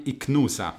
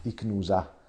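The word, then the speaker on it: Ichnusa: com'è che si pronuncia? Quella sarda, non filtrata. – La I- Icnusa. Icnusa.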